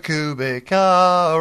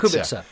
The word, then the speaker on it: Kubica: Robert Kubica, Robert, and it's not, it's actually Robert Kubica. – Kubica, yeah.